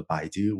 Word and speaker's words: Baidu: And is it pronounced Baidu? Forgive me for not knowing. – Oh, yes. I started and led the Google Brain team. I also ran AI for Baidu, which is a large web search engine company in China.